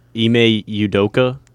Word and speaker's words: Ime Udoka: But the Celtics are having quite the last 24 hours as head coach Ime Udoka. Did I say that right? You're my maybe. – Pronunciation guy. – Ime Udoka? That sounds right to me.